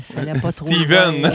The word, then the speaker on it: Stephen: C'est comme Stephen Harper. On dit Stephen, mais ça s'écrit Stephen.